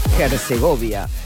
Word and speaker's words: Herzegovina: Herzegovina. Hay que pronunciar bien todo en el mundial. Es Bosnia-Herzegovina.